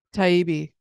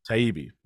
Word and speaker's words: Taibbi: But Friday, Elon Musk and uh, Matt, is it Taibbi? Am I saying that right? – Taibbi. – Taibbi. Matt Taibbi uh, got together and published a bunch of internal stuff from Twitter, basically showing how it how it went down.